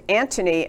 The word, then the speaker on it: Antony: And his name is Antony, and I'm not mispronouncing it. It's A-N-T-O-N-Y.